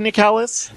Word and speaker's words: Nicalis: Aw, Nicalis, am I saying that right? Nicholas? Nicalis? – I've always said Nicalis.